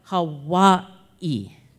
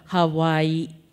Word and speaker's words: Hawaii: When actually, if you look at it, between those two I's, the first of the two I's is actually going to be the second to the last syllable. So then it would come out Hawaii.